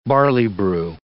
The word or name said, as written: barley-broo